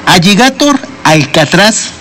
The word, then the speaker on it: Alligator Alcatraz: Y es que Alligator Alcatraz, así se pronuncia,